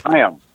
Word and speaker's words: Chaim: Okay, that lake in Scotland, Loch Ness, mm-hmm. – or Loch Lomond, or any of those lochs up there in Scotland. That's how you say that. So it's Chaim.